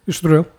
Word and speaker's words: Estoril: De São João, de Estoril. Am I saying that right? – Yeah. Castelinho de São João. – No, Estoril. – Estoril.